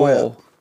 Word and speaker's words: WHP: Whip. It's pronounced whip.